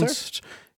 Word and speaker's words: Leicester: They spell it all weird, but then they're like, it's pronounced. Leicester? Yeah. Right? – Yeah, it's like Leicester.